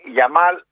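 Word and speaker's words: Yamal: Ο Κομπαρσί, ο Πέδρη και ο Λαμέγια. Συμφωνείς? – Ο Γιαμάλ λες. – Ο Γιαμάλ, ναι, εννοείται. – Ναι, Γιαμάλ.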